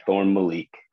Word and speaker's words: Thorne Malik: Am I saying that correctly? – Yeah, Thorne Malik.